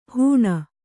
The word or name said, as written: ಹುಣ್